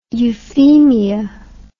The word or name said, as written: euphemia